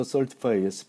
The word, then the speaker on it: Certiphi: 여기서 Certify의 스펠링은 C-E-R-T-I-P-H-I Certify 자,